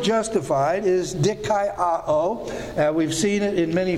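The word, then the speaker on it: dikaioō: D-I-K-A-I-O-O. Dikaiō. These are a diphthong, A-I pronounced I, and then short O, A, and then long O, O. Dikaiō. Dikaiō. And so that's how that would be pronounced.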